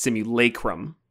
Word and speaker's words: simulacrum: It's actually, just to confuse even more, I did a little pronunciation thing here. It's simulacrum, not lacrum.